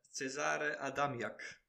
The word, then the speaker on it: Cezary Adamiak: If you'd like to do the honours of pronouncing this guy's name. – Yes, Cezary Adamiak. There you go. – Polish right back, 21 rated. I think that's a proper good pronunciation from you.